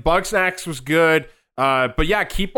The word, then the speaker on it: Bugsnax: You guys need to, you know, Bugsnax was good.